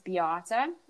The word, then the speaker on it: Beate: But if I were to look at a word, my name's Beate. It's spelled B-E-A-T-E.